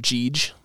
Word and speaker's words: Jeej: Another shameless plug, it's under the name Jeej. That's what my nickname is. – Spell it. – J-E-E-J. – Okay, so exactly like it sounds. – Yep, yep. – And or it could be G. G-E-E-G.